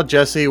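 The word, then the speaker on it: Djesse: It's supposed to have the title Jesse, which is pronounced with a silent D, is a reference to Collier's own initials.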